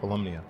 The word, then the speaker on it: Volumnia: Volumnia.